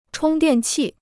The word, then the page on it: 充电器: chōng diàn qì